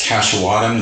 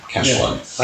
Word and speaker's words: Kaisowatum: And my last name is Kaisowatum. That's Kaisowatum. Kaisowatum. – Yeah. I pronounce it as is.